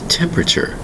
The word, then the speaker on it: temperature: Today's word is temperature. Temperature. We have four distinct syllables. Temperature, spelled T-E-M-P-E-R-A-T-U-R-E.